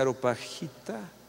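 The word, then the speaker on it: Areopagita: Dionisio la Aeropagita ¿cómo se dice? Aeropagita o apaguita. ¿Eh? Aeropagita.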